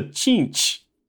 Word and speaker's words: Tzeentch: So let's move on to Cheench, which is spelled T-Z-E-E-N-T-C-H.